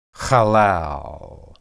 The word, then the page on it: halal: Hebrew